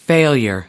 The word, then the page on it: failure: American English